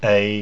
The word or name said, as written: ei